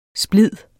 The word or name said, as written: splid